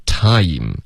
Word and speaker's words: time: 尤其是这一个字 time, 很多的学生发出来的时候呢对方会愣一下听不懂。 OK, 因为中文的发音喜欢用一个太代替这个他以的双元音 ,OK? 所以 time, 对方是听不懂的 ,OK? 你一定要发一个他然后滑到一个一他以你滑到一个一后呢你的嘴唇才能合上 ,OK?